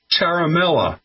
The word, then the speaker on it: Ciaramella: I mean, you can't make that up, but it's pronounced like Charlie Ciaramella.